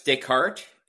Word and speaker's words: Descartes: Yeah, it's Descartes. It is a Canadian firm. And so you've got that French pronunciation.